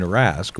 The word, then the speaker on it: Rask: The other thing I mentioned to you just last night when we were talking, Joe Bowen rolls the R in Rask when he says Tuukka Rask's name.